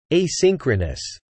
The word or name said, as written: asynchronous